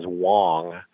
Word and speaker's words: Wong: My last name is Wong, W-O-N-G, not Wan or Long.